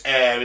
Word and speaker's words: Ayr: Because it's air in Scotland. They're calling it Area 51. I like it. – For the listener, how do you spell air? – Air is spelled A-Y-R. It's Scottish.